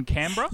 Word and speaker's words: Canberra: How is you're in Canberra? – Yep, in Canberra.